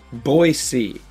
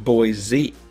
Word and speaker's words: Boise: Pronounce Boise, not Boise. Locals will know the difference.